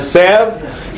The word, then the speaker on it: salve: And he placed it as a, I don't know how to pronounce this word, sav. How do you pronounce that? S-A-U-V-E, I think. – A salve.